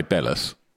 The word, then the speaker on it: Belhus: But it's not difficult to say Bellas. – All right, mate. Show off. – Can you just say it properly once? Spelt. – Bell. Yeah. – B-E-L-H-U-S. Bellas.